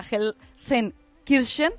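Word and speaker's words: Gelsenkirchen: Y por cierto, lo que sí ha sido noticia, sí que ha llegado hasta aquí, hasta Gelsen Kirchen, ahora ya lo pronuncio bien, por fin.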